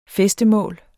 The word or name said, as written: fæstemål